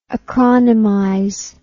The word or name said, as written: acronymize